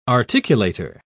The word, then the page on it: articulator: British English